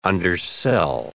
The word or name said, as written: undersell